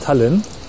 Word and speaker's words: Tallinn: spricht man das Tallinn, so? ja, Tallinn. Die, die, Deutschen sprechen das wohl so aus. Die Estländer, glaube ich, anders.